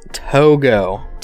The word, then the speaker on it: Togo: How to pronounce Togo? I'm gonna say it's Togo. Do you say Togo? What do you think? – I don't even know which one I originally said. – Let's go. Oh, I just typed it in. It's pronounced Togo.